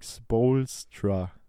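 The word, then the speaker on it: Spoelstra: Eric Spolstra ich hoffe, ich habe es richtig ausgesprochen. – Ja, ja.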